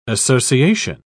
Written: association

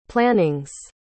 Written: plannings

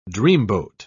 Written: dreamboat

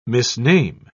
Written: misname

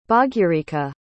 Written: Bhagyarekha

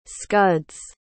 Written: scuds

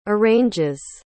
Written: arranges

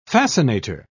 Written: fascinator

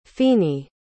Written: fini

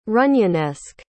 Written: runyonesque

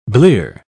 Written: blear

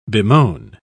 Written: bemoan

